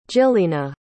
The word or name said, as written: jyllina